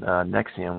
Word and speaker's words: NXIVM: He's got tons and tons of information on Nexium, which is spelled N-X-I-V-M,